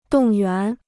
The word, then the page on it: 动员: dòng yuán